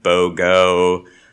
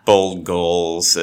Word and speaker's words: bold goal: um, it is commonly mispronounced. So I've heard BOGO, Gold Bowl, Bold Goals. It's uh, there's there's lots of permutations on that, but it is the bold goal.